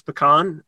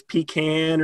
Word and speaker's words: pecan: We're known for pecan production, and yes, that is the proper way to pronounce pecan. I've seen it, and if you ever hear it as pecan or something, that's wrong. It's pecan.